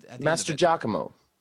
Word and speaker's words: Mastrogiacomo: now I believe it's Mastrodiacomo, but what? – That actually sounds good. – But because it's like Italian, I think it could actually be Mastrogiacomo. – Yeah, it is spelled like Giacomo.